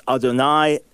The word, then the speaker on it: Adonai: Okay, the further you get into it, you will see that the Masoretic scribes very clearly were understanding that this word you're going to pronounce Adonai, and that's indicated by the vowels before it and afterwards.